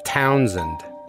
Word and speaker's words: Townsend: With the emphasis on town, and the SH turns into a Z, so it's Townsend.